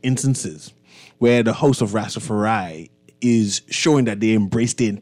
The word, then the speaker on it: Rastafari: Just now, we had members of the Royal Bahamas Police Force on, but now we have some representative from the host of Rastafari.